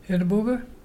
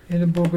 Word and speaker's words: Irrabuga: Our local Mulgana word today is Edinburgh. Edinburgh is a dolphin.